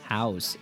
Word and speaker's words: Howes: Howes. It's Howes. It's like Howes. How, but with an S on the end.